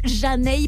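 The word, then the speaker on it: Zhané: Hey Mr. DJ sur leur album Pronounced Janae parce qu'on prononce très très mal leur nom. C'est vrai que si vous cherchez ce titre, eh ben, il faut taper avec un Z. Z-H-A-N-E, les Janae en 1994 pour vos classiques R&B que vous retrouvez fidèlement dans Classic Move.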